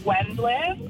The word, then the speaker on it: Wendland: My maiden name is Wendland. – Okay. Wendland. – For some reason, so one, you're back of the line all through school, right? W. For some reason, everybody pronounces it Wendell. I don't know why you disregard the last few letters, but all of the time it's mispronounced.